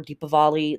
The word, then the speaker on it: Deepavali: we have Diwali or Deepavali. It's pronounced both ways.